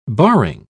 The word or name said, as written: barring